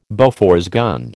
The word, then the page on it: Bofors gun: American English